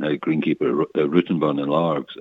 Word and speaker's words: Largs: Not bad from the boy from west of Glasgow, Largs, is that how it's pronounced? – That was it, yeah, seaside town.